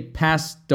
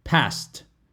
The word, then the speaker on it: passed: Typically, if we're saying it in the past tense, then we say passed away. Passed. Passed with a T sound. Passed. Kind of like past tense, right? The same pronunciation as P-A-S-T. It's the same pronunciation, but P-A-S-S-E-D.